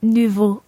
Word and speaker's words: nouveaux: J'ai écrit deux nouveaux livres. – Exactly. And there we have nouveau with an X on the end, which is silent. And of course, livre with an S on the end, which is also silent.